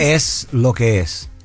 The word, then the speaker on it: es lo que es: Es lo que es? – Es lo que es.